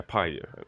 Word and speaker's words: Pi: Men om du säger Life of i början så måste du säga Pi. Det vore dumt om du fortsätter på svenska helt plötsligt.